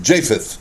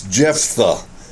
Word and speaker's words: Jephthah: My whole life I have mispronounced his name. I have been calling him Japheth, and it's Jephthah.